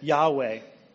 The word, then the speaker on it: Yahweh: And what it is, as we pronounce it, Yahweh.